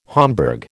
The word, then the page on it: Homburg: American English